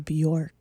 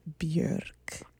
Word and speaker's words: Björk: That's how she pronounced her name, okay? – Okay. – And it's a post-colonial choice. – Okay. – She's like, the Danes got you out here pronouncing Björk. But my name is Björk.